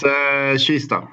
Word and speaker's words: Kista: I always say Kista, but is it Schista? Chista? How do you pronounce it? – It's Schista.